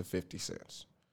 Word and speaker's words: 50 Cent: So 50 cents. – Cents. – 50 cent. – 50 cents. That's all I got. – Oh, is it not? Oh, shit, I've been saying it wrong all my life.